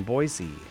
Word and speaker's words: Boise: Talking with Linda in Boise, Idaho, Salt and Light Radio. Linda didn't mention it, but I'm really good at pronouncing Boise. You don't say Boise.